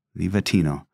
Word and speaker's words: Livatino: Rosario. It means rosary. Rosario. And Livatino is Livatino. – Okay. Livatino.